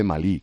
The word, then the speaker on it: Malí: Porque se dice Malí, con acento en la I, que tú me lo enseñaste.